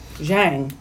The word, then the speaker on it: Zhang: Which is why it's pronounced Zhang. Like you said. – That's right. Not Yang. It's Zhang.